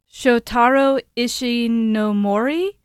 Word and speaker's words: Shotaro Ishinomori: Shotaro Ishinomori.